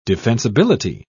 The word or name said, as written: defensibility